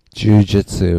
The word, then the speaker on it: jiu-jitsu: How do you pronounce it? – Jiu-jitsu. – Jiu-jitsu.